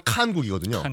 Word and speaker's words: 칸국: 정확한 발음은 칸국이거든요.